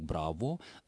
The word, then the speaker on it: браво: Его можно, кстати, произносить и как «браво», и как «браво», артикулируя «о», как было принято в дореволюционной России в такого рода заимствованиях.